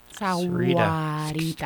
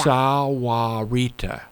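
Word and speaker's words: Sahuarita: Dan Scheer running those papers, and Dan always accuses me of mispronouncing Saharita, the H being silent. – Sawarita. – Give it to me. – Sawarita. – Sawarita.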